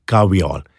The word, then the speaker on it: kahweol: K-A-H-W-E-O-L, caviol. And then there's something called cafestol, C-A-F-E-S-T-O-L.